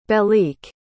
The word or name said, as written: belleek